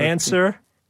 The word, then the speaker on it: answer: Or answer? – W.